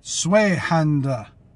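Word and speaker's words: Zweihänder: So I ended up, I don't know how to say the name. It's just, I think it's Swedish, the Zwillhander. You know what? I'm going to be right back, because I'm going to figure out how to say this. Swayhander. Swayhander. That's what that little YouTube thing kept on saying.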